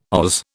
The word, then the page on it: Oz: British English